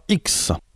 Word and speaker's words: Hiks: Après Digresque, on écoutera X. Alors attention, ça s'écrit H-I-K-S, pas comme la lettre X.